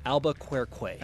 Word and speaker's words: Albuquerque: No, say it right, dude. Albuquerque. Albuquerque.